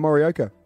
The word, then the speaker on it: Morioka: Morioka.